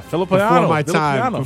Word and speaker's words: Villapiano: Yeah, Villapiano.